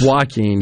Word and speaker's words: Joaquin: Joaquin, excuse me, of course, the J, don't cancel me for that, would be pronounced as, uh, I guess, a, a W in that case, right?